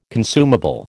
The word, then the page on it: consumable: American English